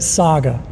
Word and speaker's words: Sagoh: You know, really poorly, we said it Saga. You know what I'm saying? S-A-G-O-H, Saga 24/7. – That makes more sense. – But, you know, but all the time, they'd be like, next up we have Sago.